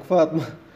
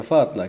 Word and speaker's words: فاطمة: فاطمة لا تنفعك فاطمة ولا عيشة هي النطق تاع فاطمة هنا هي فاطنة كما يقال آه، وليس بالميم يعني